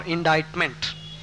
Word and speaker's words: indict: So the antonym for absolve will be convict, C-O-N-V-I-C-T is the antonym for absolve. Another antonym for absolve will be indict, I-N-D-I-C-T. In indict, the C is not pronounced, so it is indict or indictment. Next word is abstain, A-B-S-T-A-I-N. Abstain means to give up, abstain from smoking. Abstemious, A-B-S-T-E-M-I-O-U-S.